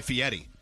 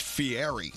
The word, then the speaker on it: Fieri: And by the way, people were saying, why are you calling him Guy Fieri? It's Fieri.